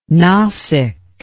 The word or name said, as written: Nasik